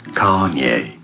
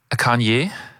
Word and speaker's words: Kanye: Kanye. – Kanye, finde ich, das ist doch so super. – Ich glaube, die Betonung ist auf der ersten Silbe.